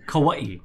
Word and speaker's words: Kauai: Kauai is fine, we say Kauai. – It's okay. It sounds like it's less work to say Kauai.